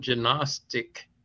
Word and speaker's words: Gnostic: But as it turns out, the term Gnostic is incorrect. That's the English pronunciation. If you look it up in any of their dictionaries, the G is silent because they avoid the explaining just what the term meant.